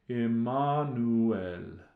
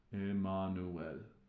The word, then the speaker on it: Emmanuel: One little difference, and you probably already noticed it, is the pronunciation of Emmanuel. Um, when we sing, Oh come, oh come, Emmanuel, the Latin pronunciation is a little different. Emmanuel. Emmanuel.